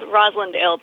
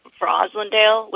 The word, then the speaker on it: Roslindale: Rosendale? – It's called Roslindale, but apparently the true Boston way to say it is Roslindale with a V in the front.